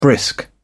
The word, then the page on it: brisk: British English